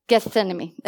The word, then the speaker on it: Gethsemane: Gethsemane. Okay, yeah, I'm not really, how do you say it? Gethsemane.